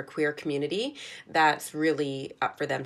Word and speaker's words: Qmunity: Talking about this, I want to give a shout out to an organization based here in Vancouver called Community. So it's spelled Q-munity, the letter Q. They do such important work supporting queer individuals and families. And on their website, which is community.ca, Q, the letter Q, M-U-N-I-T-Y.ca, they have the most comprehensive queer terminology glossary I've ever seen.